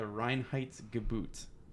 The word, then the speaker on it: Reinheitsgebot: All right, so today we're going to be talking about uh, the German beer purity law known as the Reinheitsgebot. That's how it's pronounced, yeah.